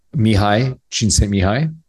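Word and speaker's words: Mihály Csíkszentmihályi: Mihaly Csikszentmihalyi.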